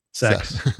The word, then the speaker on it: CeX: It's CEX, but it's pronounced sex.